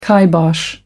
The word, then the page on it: kibosh: American English